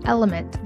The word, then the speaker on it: LMNT: This podcast is sponsored by Element. That's spelled L-M-N-T.